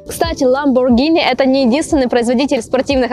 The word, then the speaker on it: Lamborghini: Еще одна марка машины, которую многие люди называют неправильно, говорят Lamborghini. Так вот, нет, не Lamborghini, а Lamborghini. Согласно правилам итальянского языка, если после G стоит ака, это H в английском варианте, то эта буква не читается и остается только G, поэтому Lamborghini, ребята.